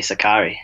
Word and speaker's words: Sakkari: You hook up with Maria Sakkari, and, and I've heard her name pronounced Sakari Sakari. Uh, can you can you confirm? How do you pronounce it? – I say Sakari.